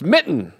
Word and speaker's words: mitten: Well, it's mitten. – There you go. – There is a T in there. – But she says no T. – You say it like she does.